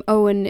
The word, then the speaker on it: Eoin: And I don't even realize that their pronunciation is counterintuitive until I meet someone who isn't familiar with them and they think the name Owen is Eoin, for example.